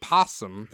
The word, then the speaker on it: opossum: A few weeks ago, we were playing Scattergories, and we got in an argument if there's a difference between opossum and possum. – There is not. – There is not. Mm-hmm. It's just the O is silent. – That's right. – In possum.